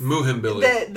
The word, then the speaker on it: Muhimbili: It's Muhimbili. – The M is silent. It's Umbili.